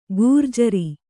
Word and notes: ಘೂರ್ಜರಿ: kannada

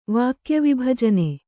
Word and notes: ವಾಕ್ಯವಿಭಜನೆ: kannada

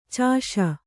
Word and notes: ಚೂಷೆ: cūṣe

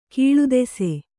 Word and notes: ಕೀಳುದೆಸೆ: kīḷudese

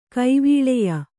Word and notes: ಕೈವಲ್ಯ: Kannada

